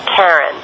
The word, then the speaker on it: Charon: These indicate only one pronunciation when specifically referred to Pluto's moon with an initial K sound, Karen. Speakers of the languages other than English and many English-speaking astronomers as well follow this pronunciation. However, Christie himself pronounced the CH in the moon's name as SH, and they used the Greek the Greek, after his wife Charlene.